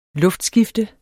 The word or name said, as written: luftskifte